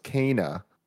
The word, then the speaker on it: Kena: Kena Bridge of Spirits is pronounced Kena per a Game Informer interview.